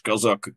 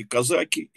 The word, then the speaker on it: казаки: А вот когда говорят о казачестве, то применимы оба ударения, и казаки, и казаки.